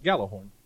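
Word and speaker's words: Gjallarhorn: It doesn't have a J right after the G. – The J is silent. – Galahorn.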